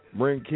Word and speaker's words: Bryn: Bren King. That's what's happening. Now it's spelled Brian, yeah. – right? – Yeah, it's spelled B-R-Y-N. Spelled B-R-Y-N-K-I-N-G, but it's pronounced Bren.